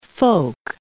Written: folk

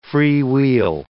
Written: freewheel

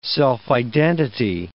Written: self-identity

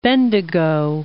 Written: Bendigo

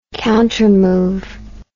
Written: countermove

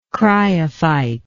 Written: cryophyte

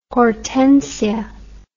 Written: hortensia

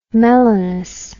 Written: melanous

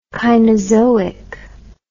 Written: Kainozoic

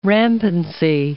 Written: rampancy

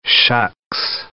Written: shucks

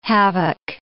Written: havoc

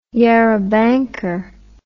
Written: yarrabanker

